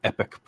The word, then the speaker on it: EPYC: Basically, AMD unveiled what they're calling their EPYC processors. EPYC spelled like a cool E-P-Y-C, by the way.